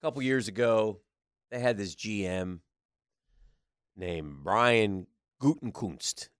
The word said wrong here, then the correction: Gutekunst: There's no N in there, so it's Gut, Gutekunst.